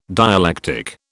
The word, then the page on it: dialectic: British English